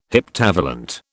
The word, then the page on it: heptavalent: British English